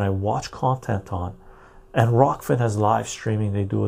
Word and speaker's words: Rokfin: and there's Ruffkin and there's so much other stuff. I get notifications from Odysee, I get notifications from Ruffkin, I get oh, or not Rofkin, uh, Rokfin. I pronounce it.